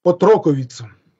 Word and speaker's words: отроковица: И правильнее звать не девушка, а отроковица. Отроковица, на ударение на «и».